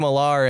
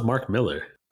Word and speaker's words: Millar: Whoa, is that how you pronounce it? Millar? I thought it was Mark Miller. – I thought it was Mark Miller too. – For the longest time I did, but it's spelt M-I-L-L-A-R. – I know, but it's, I feel like things are still like. – He's also like, I think he's Scottish or British. – Pillar is spelled P-I-L-L-A-R.